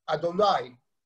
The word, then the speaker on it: Adonai: The name of God in all our prayers, Yud Hey Vav Hey, which is not pronounced Yud Hey Vav Hey, we say Adon, Adonai, Master or Lord.